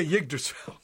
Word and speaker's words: Yggdrasil: And finally, Moon X with the, I don't know how to pronounce this here, Yggdrasil. Help me out, guys. – Yggdrasil? – Okay, Yggdrasil, of course.